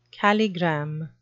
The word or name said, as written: calligram